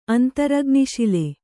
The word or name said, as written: ಅಂತರಗ್ನಿಶಿಲೆ